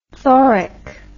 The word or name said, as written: thoric